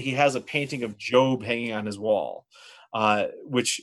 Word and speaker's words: Job: J-O-B, it's spelled Job, but it's pronounced Job.